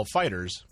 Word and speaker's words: FighterZ: Also out now is Dragon Ball FighterZ, which is actually pronounced Dragon Ball Fighters by the developers.